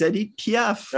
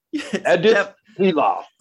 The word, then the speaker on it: Edith Piaf: I was like, That's the French pronunciation. – That's her name. It's Edith Piaf. Right. And you would think a French guy would know that. – Edith Piaf. – It's definitely not Edith.